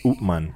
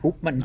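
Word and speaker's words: Upmann: Upman. – Upman. – I always say Upman. I pronounce it like a Spanish, I guess.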